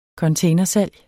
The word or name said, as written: containersalg